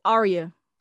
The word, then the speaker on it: Aria: Aria.